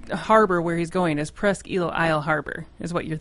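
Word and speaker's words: Presque Isle: It's Presqu'ile, not Presqu'ile Isle. – Jesus. – It's just Presqu'ile. – Okay, thank you. – Presqu'ile. I know it's spelt Isle, but it's eel.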